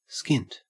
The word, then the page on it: skint: Australian English